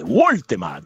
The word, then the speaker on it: Woltemade: Ma vediamo la Gazzetta dello Sport, segno subito, è sbarcato Woltemade, il gigante della Juve, Woltemade, oh, mi hanno detto che si pronuncia così, io tedesco non lo so,